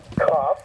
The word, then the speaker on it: Kaf: Yud, Kaf, so it's pronounced with that kind of A as in father, Kaf.